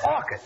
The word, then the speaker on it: orchids: Last night, I took my girl to a movie and bought her some orchids. – It's not orchids, it's orchids. The C-H is pronounced like K.